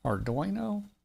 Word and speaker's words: Arduino: Arduino? – Yep, you got it.